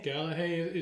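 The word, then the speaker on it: Gallagher: And I was going to talk about my boy James Gallahay? – Gallahay is correct. – Is at least how he pronounces it.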